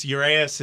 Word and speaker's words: Urias: Did I butcher his name? – Urias. – Urias? – Said, yeah. – Okay. I said that's Urias. Urias.